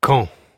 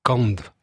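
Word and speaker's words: Kañv: Et ça s'écrit K-A-N avec Clotilde et un V. – Et un V, oui. Ouais. Qu'on peut prononcer O ou qu'on ne prononce pas. Voilà. Hein? Donc si on le prononçait sans, s'il n'y avait pas de tilde, ça ferait Kand.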